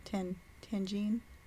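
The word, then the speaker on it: tahini: We're having the regular lemon lime margaritas today, and I brought some tangine. I think is the way you pronounce it. Tahine. That's the way it's pronounced.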